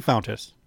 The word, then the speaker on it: Fountas: Nope, Steve Fontas.